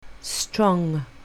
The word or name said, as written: strong